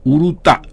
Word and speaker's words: Urutau: De Google Play o Play Store bajás Urutau, pon el acento en la última U, Urutau.